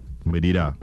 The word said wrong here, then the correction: malida: The malida. – Malida is the pronunciation? – Yes.